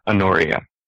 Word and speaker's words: Honoria: On a completely, well, not completely unrelated note, but not where we are, I've looked it up, and apparently it is Honoria. I also found a lot of other people who can't figure out how you're supposed to pronounce it, but the people that I've found that seem to know do put the accent there.